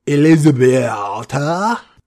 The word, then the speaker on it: Elisabetta: And on the eve of this big battle, his bride, let me see if I can get her name right, Elisabetta. Did I pronounce that correctly? – Yeah, I think that's as accurate as the English tongue can get.